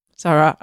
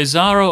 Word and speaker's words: Zahra: So Zahra or Zahra. Yeah. And different audiences say different things.